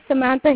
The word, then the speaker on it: Samantha: My name is Samantha Hayes.